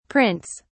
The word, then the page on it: prints: British English